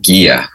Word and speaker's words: Gigha: I'm originally from Scotland, from the island of Gia, which is spelled G-I-G-H-A.